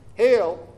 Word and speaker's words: Halle: A center of it was in Halle, I'm not sure how to pronounce it. It's Halle.